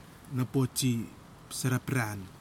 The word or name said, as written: napoti sarapiran